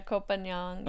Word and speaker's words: Koh Phangan: And like at that time was in just like the next island over, it was Koh Phangan. – Yes. – Did I say that right? – Yeah, Koh Phangan.